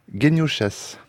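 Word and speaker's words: Geniušas: Ben, je crois Lucas Genioušas. – Oui, parce que tout à l'heure, j'ai mal prononcé, mais Genioušas.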